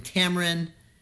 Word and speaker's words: Tamaryn: She also has a MySpace page, and her name is spelled T-A-M-A-R-Y-N, Tamarin.